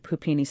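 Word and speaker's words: Puppini: I can't tell you how excited I am about the Pupini sisters. I pronounced it correctly. You did. Pupini sisters.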